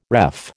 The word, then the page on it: ref: American English